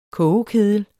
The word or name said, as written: kogekedel